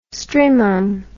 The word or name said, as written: strymon